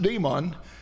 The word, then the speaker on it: Daemon: By the name of the cult was Daemon, D-A-E-M-O-N, or basically pronounced demon. We spell demon, D-E-M-O-N, but Daemon.